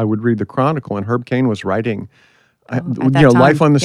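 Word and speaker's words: Herb Caen: Herb Cain. – So Herb Cain.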